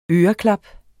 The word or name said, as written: øreklap